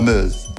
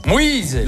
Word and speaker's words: Meuse: La Meuse. – Moïse.